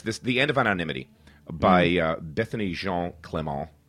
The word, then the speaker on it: Bethany Jean Clement: Bethany Jean Clement. That's fine. That's how it would be pronounced here.